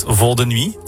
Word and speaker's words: Vol de Nuit: And also, 20 years ago, you made Vol de Nuit together. – Yeah, I hope you pronounce it in the right way. – Perfect. – Vol de Nuit. Yeah.